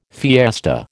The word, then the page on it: fiesta: American English